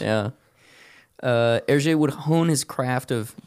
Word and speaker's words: Hergé: He created the pseudonym for Hergé by flipping his initials and then using the French pronunciation for them. So instead of G-R, he flipped him to R-G.